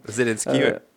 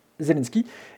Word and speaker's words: Zelensky: Zelensky, Zelensky.